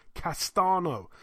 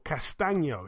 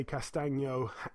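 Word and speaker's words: Castaño: Patrick Teixeira, 31-1, defends his WBO World Super Welterweight title against Brian Castano, or Castagno, it's actually pronounced. 16-0 with a draw, like I say, Castagno.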